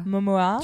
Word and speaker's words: Momoa: donc qui est joué par Jason Momoa. Je ne sais pas comment prononcer son nom de famille. – Momoa. Tu dis comme Cocoa.